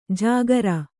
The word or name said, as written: ಝಕಾರ